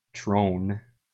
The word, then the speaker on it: Trump: It's like Donald without the last D and then like Trone.